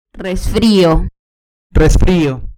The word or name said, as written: resfrio